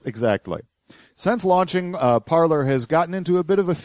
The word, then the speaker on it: Parler: Now, the interesting thing about this is that the name of the site is Parler, pronounced like the French verb to speak. But people didn't like that shit, so they just started calling it Parler. So the official pronunciation of the name has now been changed to Parler. – But it's spelled Parler? – Well, that's how P-A-R-L-E-R is how you spell the French verb, Parler.